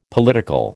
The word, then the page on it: political: American English